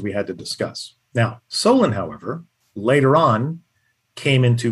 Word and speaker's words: Solon: And to give you the background on Solon, I based Solon on a historical character. I based it on the character Solon, S-O-L-O-N. Now, in the script, it's spelled S-O-L-A-N.